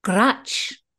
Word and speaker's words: grutch: It is spelt G-R-U-T-C-H. Grouch.